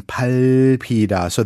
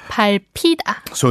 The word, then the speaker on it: 밟히다: You pronounced to step on 밟다. – Mm-hmm, correct. – And then to be stepped on 밟히다. So that lil in the second one makes a little pronunciation appearance. Did I hear that correctly? – That's right. You pronounce it 발 and then P. – That's right. – It's as if it were its own syllable.